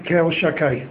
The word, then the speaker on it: Kel Shakai: It says that until now Hashem appeared with the name and all his promises and all his dealings to the Avos with the name Kel Shakai. That's how it's pronounced.